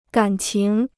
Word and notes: 敢情: gǎn qing

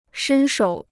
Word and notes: 伸手: shēn shǒu

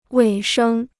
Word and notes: 尾声: wěi shēng

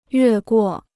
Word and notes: 越过: yuè guò

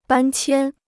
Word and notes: 搬迁: bān qiān

